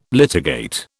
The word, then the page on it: litigate: British English